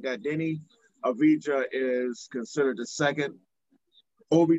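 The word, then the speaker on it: Deni Avdija: He's butchering his name. It's Denny Avija. – Denny Avija.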